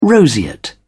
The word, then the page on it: roseate: British English